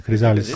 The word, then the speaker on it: Chrysalis: Come si deve pronunciare? Chrysalis. Quello è inglese stavolta.